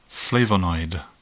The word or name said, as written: flavonoid